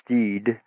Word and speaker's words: Stead: Yeah, it's primarily about William T. Stead, sometimes pronounced Steed,